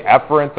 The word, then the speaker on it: efferent: Yeah, if you want to say efferent, that's proper pronunciation, but if I say it in class with the echo in here, you might confuse it with afferent. Right? So I say efferent and afferent, just to be clear.